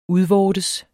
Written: udvortes

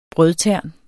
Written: brødtern

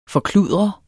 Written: forkludre

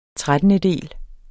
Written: trettendedel

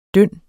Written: dynd

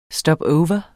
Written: stopover

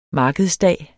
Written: markedsdag

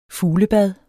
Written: fuglebad

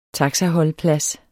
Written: taxaholdeplads

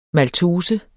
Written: maltose

